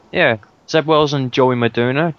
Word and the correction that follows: Madureira: I can't pronounce his surname. – It's Joe Madureira.